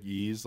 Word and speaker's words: Ys: by the way, how do you pronounce it? I think it's like YS, right? – Is it like Yeez, like Yeez. The Sega? – Yeah. – Yeez. – Oh, okay. Yeez.